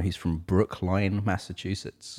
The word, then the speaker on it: Brookline: Brookline, yeah.